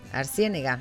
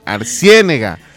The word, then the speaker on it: Arciénega: Arciénega. – Arciénega. Es que tiene el acento entre la E y la N. Arciénega.